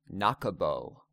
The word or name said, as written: Nakebo